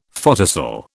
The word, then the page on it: photocell: British English